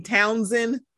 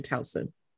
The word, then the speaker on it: Towson: It always gets me when people from Maryland say Townsend, and it's like, there's one in Towson.